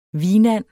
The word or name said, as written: hvinand